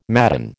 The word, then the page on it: madden: American English